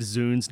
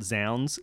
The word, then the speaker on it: zounds: I did make the very first note I have on my paper is it should be pronounced zoons, not zounds, because it's short for God's wounds.